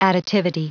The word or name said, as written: additivity